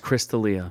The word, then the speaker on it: Chris D'Elia: So Chris D'Elia, I think that's how you pronounce his name. – Yes, Chris D'Elia.